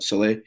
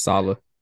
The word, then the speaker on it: Saleh: Sala.